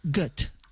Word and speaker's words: Goethe: Y según he visto, se pronunciaría Goethe. – Mira, bueno, como Gota.